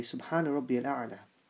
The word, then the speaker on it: Subhana Rabbiyal A'la: And we have to make sure that our pronunciation of this tasbih in our sijda is pronounced correctly, because many times we make the mistake of not pronouncing the various tasbihs that we read in salah properly. So it should be read correctly, Subhana Rabbiyal A'la.